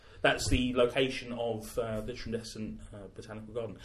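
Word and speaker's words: Tradescant: But John Trendescant went above and beyond... – I don't think you have to pronounce the C. – Do you not? – Trendescant. Yeah, okay. That's what Will Self said.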